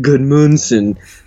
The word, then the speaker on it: Gudmundsson: He's Icelandic. – I said it right the first time. There you go. I said it right the first time. Johan Berg Gudmundsson and Harry Kane are my starts.